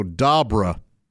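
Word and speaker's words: d'arbre: Because the French spelled it with two R's. So it's D apostrophe A-R-B-R-E, which just means tree or of the tree, essentially. So, well, we all Arbor Day, that this all makes sense. But they don't pronounce that R that we would. They just pronounce the second one. So, dabra. Ugh.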